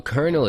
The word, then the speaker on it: colonel: Also, kernel is spelled weird. Colonel.